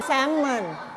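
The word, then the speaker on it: salmon: ภาษาไทยเราจะเรียกว่าแซลมอนแต่ภาษาอังกฤษอ่านแซลมอนแซลมอนไม่ออกเสียง L นะ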